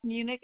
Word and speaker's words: Muench: Alexis Munich. It's spelled A-L-E-X-I-S. Last name is M-U-E-N-C-H.